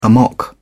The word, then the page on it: amok: British English